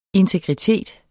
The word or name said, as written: integritet